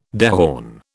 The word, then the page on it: dehorn: British English